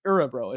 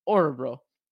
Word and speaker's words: Örebro: Örebro. It's like Höglander. It's like the O with the umlaut. It's pronounced Örebro.